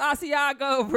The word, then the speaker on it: Asiago: He definitely saying it wrong. – Put the butter in the pan. Nah, it's Asiago, bruh.